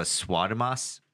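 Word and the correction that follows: Swamidass: I said that wrong. Swamidas, that's him.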